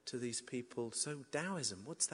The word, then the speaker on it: Taoism: Taoism used to be spelled Taoism with a T, pronounced Taoism.